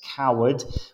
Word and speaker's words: Coward: So Sacha / Coward, which is like the cowardly lion.